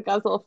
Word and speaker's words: ghazal: A gazelle. No, it's the first one to say it wrong. No, I knew I'd say it wrong at some point. I'm sorry, I was the first one. But anyway, an ars poetica about the ghazal form,